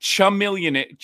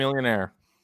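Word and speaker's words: Chamillionaire: Well, he pronounced it chameleon. He couldn't even say chameleon. It couldn't even say it. Right? – Chameleonaire.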